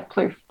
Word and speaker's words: Plouffe: But it was another sprint finish at the end, won by, I'm going to butcher her last name, but you can correct me, hopefully, Maeve Plouffe? – Yeah, Plouffe.